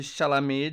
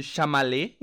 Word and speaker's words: Chalamet: Lembrando que no primeiro episódio tá causando, eu chamei o Timothy Chalamet de Timothy Chamalé, então.